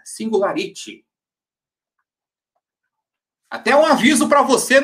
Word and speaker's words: Singularity: Aí, ó, o fala, o fala que eu te como, opa, fala como eu falo, ele disse o seguinte, Singularity. Singularity. Essa é, é a pronúncia certa.